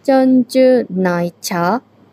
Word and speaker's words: 珍珠奶茶: ちゃんじゅないちゃへえ、全然タピオカの発音と違いますね。